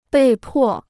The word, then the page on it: 被迫: bèi pò